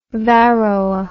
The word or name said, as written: varroa